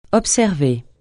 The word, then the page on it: observer: francais